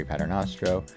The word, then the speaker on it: Paternostro: I'm your host, lead prospect writer at Baseball Prospectus, Jeffrey Paternostro. Joining me is our editor-in-chief, Craig Goldstein. Craig, did I get the proper long A sound in there? – Yeah, you did that nice.